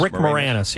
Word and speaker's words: Moranis: Actor Rick Moranis, am I saying his name right? Moranis? – Rick Moranis, yeah. Moranis.